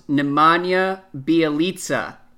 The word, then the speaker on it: Nemanja Bjelica: Andrew, it's pronounced Nemanja Bjelica.